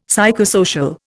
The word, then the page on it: psychosocial: Indian